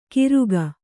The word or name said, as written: ಕಿರುಗ